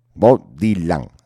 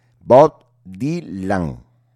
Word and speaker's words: Bob Dylan: Bob Dylan. A ver, señor catedrático, pronuncia conmigo. Bob Dylan. Bob Dylan, eso es.